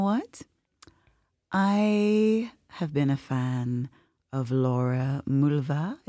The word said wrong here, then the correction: Mvula: Is that how you pronounce? – Mvula.